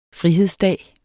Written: frihedsdag

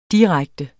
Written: direkte

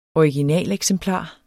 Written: originaleksemplar

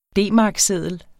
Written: D-markseddel

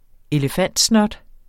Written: elefantsnot

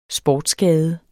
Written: SPORTSSKADE

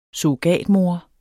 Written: SURROGATMOR

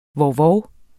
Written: vovvov

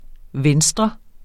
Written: venstre

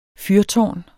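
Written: FYRTÅRNET

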